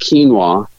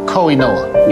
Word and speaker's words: quinoa: And when Matt introduced quinoa in 2010, people literally didn't know what it was, let alone how to say it. – Kiana. – Nope. – Koinoa. – No.